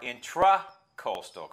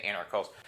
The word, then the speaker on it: intracoastal: And it's technically intra with an A, not E-R, intracoastal, correct? – Correct. – So everybody's mispronouncing it. They say intercoastal because everybody's lazy, right? But intra versus inter, you know, intra means within. So it's an intracoastal.